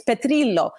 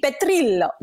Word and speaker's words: Petrillo: If we try to pronounce every single name the way it's supposed to be, for example, my last name, if you were to truly give it the Italian pronunciation, it's Petrillo. Am I honestly going to go on air every single day and be like, welcome to the show, I'm Andy Petrillo?